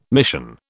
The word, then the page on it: mission: British English